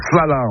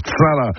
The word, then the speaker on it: chala: Bref, une précision en dauphinois, chala, ça se prononce sala, en fait, sala.